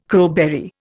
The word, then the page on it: crowberry: Indian